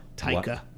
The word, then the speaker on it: Taika: Taika. – Waititi. – So it's like Tiger or like Taiga. – Taiga. – But with a K.